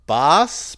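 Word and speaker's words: Bas: Boss? Bass? How do you say that? – Boss. Also from Dreamville.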